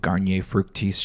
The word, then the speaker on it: Garnier Fructis: But anyway, when I was there, one of my jobs was handing out samples of Garnier Fructis shampoo.